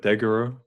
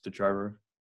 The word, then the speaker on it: Degiro: It's a brokerage called, I call it Degiro, but I think the actual pronunciation is Degiro.